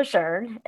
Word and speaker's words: LeJeune: I'm on Instagram as Paula LeJeune, Paula, of course, is spelled the same, last name, L-E-J-E-U-N-E.